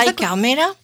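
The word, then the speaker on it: Ay Carmela: C'est Ai Carmela, j'espère que je le prononce bien. C'est comme Ai Carmela. – Ouais, je l'ai bien dit.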